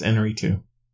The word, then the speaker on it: Iñárritu: It's Iñárritu.